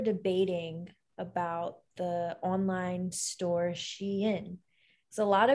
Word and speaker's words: Shein: So it's, in my, like, I'm pretty sure it is called Shein, but a lot of people pronounce it as Sheen or Shine.